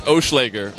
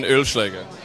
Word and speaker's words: Ölschläger: It's Oschlager, or in German, Ölschläger.